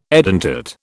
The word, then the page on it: edentate: British English